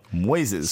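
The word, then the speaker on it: Moisés: And it's Moses, but there's an I in there as well. – Moises. – So, yeah. Moises. Sounds like Jackie Gleason.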